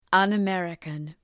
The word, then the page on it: un-American: ,ʌnə’merıkən